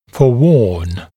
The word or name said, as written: forewarn